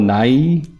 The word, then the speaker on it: Nighy: I got Mark Hamill, Jason Isaacs, Christoph Waltz, Bill Nighy, I think that's how you say his name.